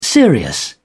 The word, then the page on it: serious: British English